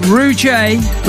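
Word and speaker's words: Ruejay: The name is spelled Rujay with an underscore music. So R-U-E-J-A-Y. R-U-E-J-A-Y underscore music.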